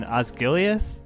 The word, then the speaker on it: Osgiliath: But, like, all of these flashback scenes in Osgiliath, I am saying that right?